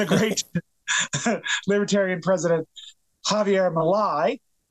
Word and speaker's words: Milei: And I think we've decided that Milei is how the last name is pronounced.